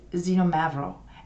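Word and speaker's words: Xinomavro: And its name is Zinomavro. And Zinomavro is spelt with an X,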